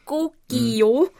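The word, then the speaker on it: Gogio (꼬끼오): genauer ausgesprochen Gogio. Mhm. – mhm. – Ähm, und eigentlich, wenn man genau hinhört, klingt das auch wirklich so, muss ich sagen. Also ich finde es ähm, Gokio ähm, realitätsnäher als äh, Kikiriki.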